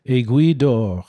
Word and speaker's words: Aiguille d'Or: Aiguille d'or.